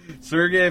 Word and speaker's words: Sergei: Sergio Fedorov, he's won an MVP. According to Wayne Gretzky, how do you pronounce it? – Sergei Fedorov.